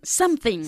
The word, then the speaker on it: something: Se dice something. Se escribe something, pero no se pronuncia something, ¿ok? Something, bajando la mandíbula para something. – Pronunciar este sonido de la vocal, ¿ok?